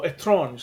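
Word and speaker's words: Étrange: I was the GM for Chateau Estrange, or Etrange, sorry, because that's the proper French.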